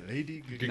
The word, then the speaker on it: Lady Gaga: Lady Gaga. – Now you said it correctly.